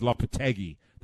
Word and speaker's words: Lopetegui: But I am told by my Spanish friends that his name is Lopetegui. That's how you pronounce it. But that's the former manager. But I like to call him Lopetegui because that's how it's spelt.